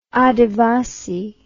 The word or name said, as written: adivasi